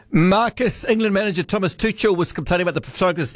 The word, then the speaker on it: Tuchel: Tuchel, his surname is pronounced.